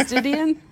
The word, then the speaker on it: Didion: It is Didion? – Yeah.